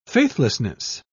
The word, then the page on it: faithlessness: British English